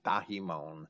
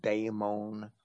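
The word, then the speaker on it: daimon (δαίμων): That's how I would pronounce that, daimon. Some scholars might pronounce it daemon.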